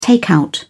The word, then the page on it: takeout: British English